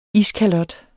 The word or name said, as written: iskalot